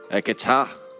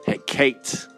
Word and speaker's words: Hekate: she was known as Hekate. Hekata. Hekate. Appropriately, many pronunciations for the single name of the goddess of sorcery.